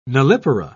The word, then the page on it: nullipara: British English